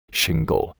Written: shingle

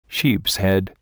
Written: sheepshead

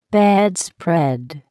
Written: bedspread

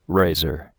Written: razor

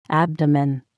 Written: abdomen

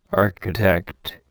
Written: architect

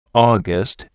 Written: August

